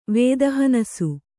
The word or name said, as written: ವೇದಹನಸು